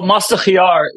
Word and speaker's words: mast khiar: Well, mast khiar, you would add the ice with. So mast khiar, khiar means cucumber.